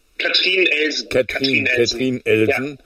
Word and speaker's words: Katrin Elsen: Aber bei mir ist aufgefallen, das ist ja besetzt wie spricht man es aus? Katharina Elsen, ne? – Katrin Elsen. – Ja. Genau, aus Luxemburg, deswegen Katrin Elsen.